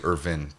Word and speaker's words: Irvine: My last name is actually Irvine, but Irvine? – Yeah, it's totally fine.